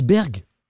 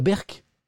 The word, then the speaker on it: Berck: Ah, on dit Berg. On dit pas Berck.